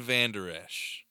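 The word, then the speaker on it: Vander Esch: I mean, Vender. Well, how do you say it? – Van Der Esch. – Yeah, I mean, that. – Leighton. Leighton Van Der Esch.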